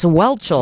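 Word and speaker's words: Whelchel: I hope he has Lisa Wenchel some lunch now. – It's Welchel. – Welchel. – First of all, get her name right.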